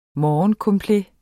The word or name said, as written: morgencomplet